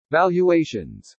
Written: VALUATIONS

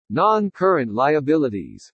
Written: NON-CURRENT LIABILITIES

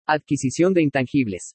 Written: ADQUISICION DE INTANGIBLES

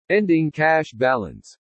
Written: ENDING CASH BALANCE

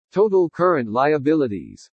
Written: TOTAL CURRENT LIABILITIES

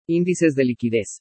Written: ÍNDICES DE LIQUIDEZ

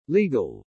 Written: LEGAL

